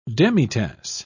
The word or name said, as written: demitasse